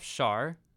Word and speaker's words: Schaar: I pronounce my last name like shark without the k. Schaar.